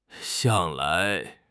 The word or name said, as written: 向来